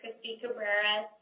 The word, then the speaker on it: Christi Cabrera: My name is Christy Cabrera, C-H-R-I-S-T-I-C-A-B-R-E-R-A, and I'm the Policy and Advocacy Director for the Nevada Conservation League.